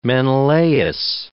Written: Menelaus

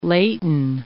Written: laten

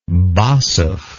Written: Basov

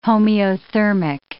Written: homeothermic

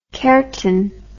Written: karnten

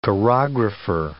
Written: chorographer

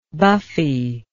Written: buffy

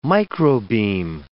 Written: microbeam

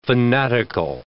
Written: fanatical

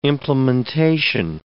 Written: implementation

